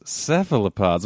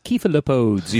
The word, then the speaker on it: cephalopods: Today, the topic is cephalopods. Am I pronouncing that correctly? – Yes. You are, yeah, but I think British people say, like, cephalopods, you know, or something like that.